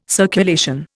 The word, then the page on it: circulation: Indian